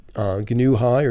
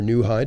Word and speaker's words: Gnu High: This release called Gnu High, or New High, depending upon which pronunciation you like.